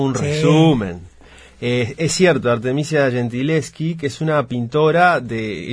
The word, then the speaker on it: Gentileschi: Si hoy conocemos más Artemisia Gentileschi o Gentileschi, no sé cómo se pronuncia exactamente. – Gentileschi, me imaginé.